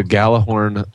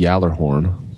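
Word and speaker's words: Gjallarhorn: I guess if I was pronouncing it correctly, it would be the Yallerhorn, because it's a Swedish word, right?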